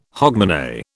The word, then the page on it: hogmanay: British English